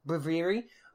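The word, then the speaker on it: Braviary: I also finished my Ultra Sun with Breviaria, and that's how you pronounce it? – Breviary.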